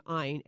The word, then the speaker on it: Ayn: And I, I really do include Judeo Christian culture in this contrasted with Anne, uh, uh, she pronounced his name Ayn, A N Y, Ayn Rand, you know, the famous author, huge libertarian, uh, who said essentially her view on, views on charity are very simple.